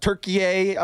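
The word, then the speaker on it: Türkiye: It's spelled, pronounced, the official, like if you looked up Turkiye on the UN website or whatever, it's Turkiye.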